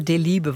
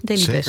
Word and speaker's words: Delibes: Ze kregen echt een half A4'tje sarcasme dat ik niet wist wie Leo Delibes was. Want dan zo moet je natuurlijk uitspreken. Dus dat weet ik. Maar nu is het toch goed, Delibes.